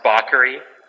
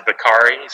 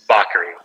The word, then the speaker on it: Bacari: Uh, well, technically, it's pronounced Bacari, uh, but I would say 98% of people who it's, uh, pronounce it Bacari. So, really, at the end of the day, I'm not so concerned with how people say it. But technically, it's bakery.